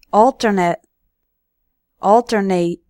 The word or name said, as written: alternate